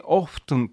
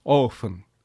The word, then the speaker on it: often: 英語には例えば時間の hour の H だとか、 often、 often と書いて、実際に often と発音する人もいますが、まあ大部分は often、 T は無声音になります。